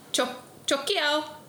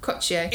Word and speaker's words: Cocio: Chocchio. – There's no H. Cocchio? – Cocchio.